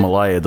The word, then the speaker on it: Malia: Yeah, is it Malia? – Malia, the older one.